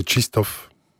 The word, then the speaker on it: Krzysztof: En fait, ça se prononce, c'est un nom qui vient du polonais. C'est Krzysztof, c'est comme ça qu'on le prononce.